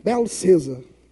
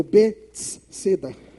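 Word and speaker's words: Bethesda: no aramaico se pronuncia dessa maneira, Belcesa, ou Betseda.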